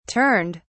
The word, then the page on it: turned: British English